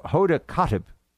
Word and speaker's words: Hoda Kotb: Hoda Kotb. Am I saying that right, Producer Mike? I never watch these shows, so I don't know these things. – I don't watch Hoda, either, but yeah, it sounds right.